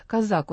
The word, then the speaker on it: Козак: Хотя, по-моему, президент все-таки делает ударение на втором слоге фамилии Козак. Казак он его вроде бы вчера назвал. Все мы путаемся, но мы будем, наверное, я по инерции Козак буду говорить.